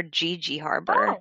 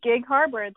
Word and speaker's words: Gig Harbor: Is it Gig Harbor or GG Harbor? Oh. – Mm-hmm. Gig Harbor.